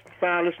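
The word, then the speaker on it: Phineas: Well, now, you pronounce his name, you know, several different ways, Finneas and Finneas. So how do we really pronounce it? – Finneas Newborn Jr. But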